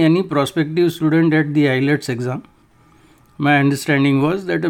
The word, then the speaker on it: IELTS: because the prospective student has to pass an exam called IELTS, pronounced ILETS, with at least a minimum score.